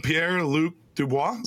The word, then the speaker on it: Pierre-Luc Dubois: We landed Pierre-Luc Dubois.